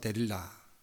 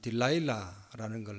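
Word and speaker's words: Delilah: 뭐 제 기억에는 자주 틀어준 외화가 있는데 그게 벤허하고 삼손과 데릴라. 나중에 이 데릴라가 우리 미국식으로 발음하면 딜라일라라는 걸 나중에 뒤에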